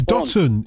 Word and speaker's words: Dotun: It's D-O-T-U-N. – Rhymes with Gooden. I remember that anyway. – It rhymes with a lot of things that my missus isn't too happy with. Rotten, I think, is chief amongst the ones that she doesn't like.